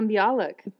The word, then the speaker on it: Bialik: And- That was Maya Bellick, or however you say her name. – Maya Bialik.